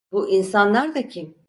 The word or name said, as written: insanlar